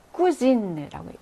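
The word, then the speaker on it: cousine: Je suis avec ma cousine라고 얘기하죠. 쿠진은 사촌인데 여성이에요. 그래서 쿠진이라고 발음을 하고요. 사촌인데 남성이면은 끝에 의가 없어지니까 이때는 쿠쟁이 되죠. 발음이 달라집니다.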